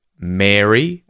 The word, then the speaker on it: Mary: Be careful with its pronunciation, though. Don't confuse it with Mary, as in the name, which is the long air vowel sound, as opposed to the short e in Merry, or with the vowel sound a in marry, as in to marry someone.